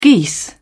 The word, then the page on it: geese: British English